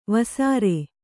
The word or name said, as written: ವಸಾರೆ